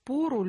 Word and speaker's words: пору: Наталья спрашивает, как правильно произносится слово «пора» в винительном падеже? Репетируют «пору любви» или «пару любви»? – Пору.